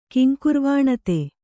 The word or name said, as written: ಕಿಂಕುರ್ವಾಣತೆ